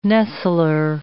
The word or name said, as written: nestler